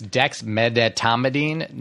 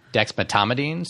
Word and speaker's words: dexmedetomidine: It's dexmedetomidine, not dexmedetomidine. So people leave out that middle syllable.